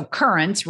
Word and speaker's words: Currence: So Currents rhymes with insurance.